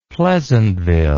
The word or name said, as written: pleasantville